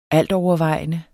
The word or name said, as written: ALTOVERVEJENDE